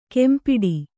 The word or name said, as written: ಕೆಂಪಿಡಿ